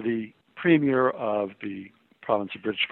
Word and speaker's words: premier: It's P-R-E-M-I-E-R. There's no change in that spelling, and it's always pronounced premier. It is not premier or premier or premier, which are just 3 of the many pronunciations of those various words that Americans have.